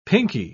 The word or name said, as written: pinky